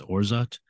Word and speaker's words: orgeat: Made with Caracara Orange, Passion Fruit Lemon, Almond or Orzat, which I had never heard before. It's spelled O-R-G-E-A-T, but I guess it's pronounced Orzat, and Lime Syrup.